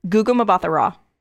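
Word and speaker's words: Gugu Mbatha-Raw: Guga Mbatha Raw.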